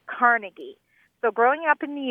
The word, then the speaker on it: Carnegie: Yes, and if you go to his little hometown in Scotland, that's what you'll hear again and again. – Carnegie. – Accent on the second syllable. – With more of an A than an E.